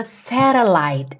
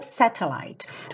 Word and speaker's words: satellite: I am a satellite. Essa palavra satélite em inglês é satellite ou satellite. O T você pode pronunciar como T, que é até uma característica mais britânica, mas aqui tá satellite. Satellite.